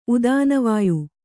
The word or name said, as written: ಉದಾನವಾಯು